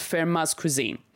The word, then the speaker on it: Fermat's Cuisine: is a Japanese series called it's pronounced Fermat's Cuisine.